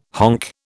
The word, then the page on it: honk: British English